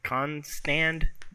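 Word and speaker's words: Constand: The main woman behind all this was a woman named Andrea Konstad. Konstand, that's how you say it.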